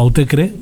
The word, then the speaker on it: Autechre: Es un dúo Autecre, lo pronuncian ellos, aunque es una palabra inventada.